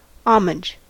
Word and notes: homage: American English

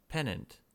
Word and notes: pennant: American English

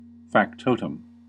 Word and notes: factotum: American English

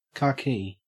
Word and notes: khaki: Australian English

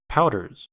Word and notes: powders: American English

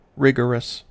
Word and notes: rigorous: American English

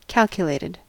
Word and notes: calculated: American English

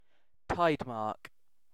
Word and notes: tidemark: British English

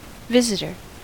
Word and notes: visitor: American English

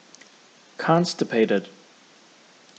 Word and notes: constipated: American English

